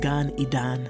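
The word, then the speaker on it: Gan Eden: In Hebrew, the garden is Eden, but it's pronounced Gan Idan.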